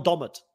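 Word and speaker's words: Dommett: Joel Domet. – Yeah, Joel Domet. That's how it's pronounced. Yeah, Domet, I asked Bandit Survivor.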